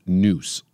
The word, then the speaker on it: nous: And one of the great things to know about this was that Pericles practiced something called nous, N-O-U-S, N-O-U-S-E, I believe it is. N-O-U-S, but it's pronounced noose, like N-O-O-S-E.